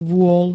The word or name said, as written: vôl